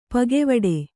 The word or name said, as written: ಪಕ್ಕೆವೆಟ್ಟ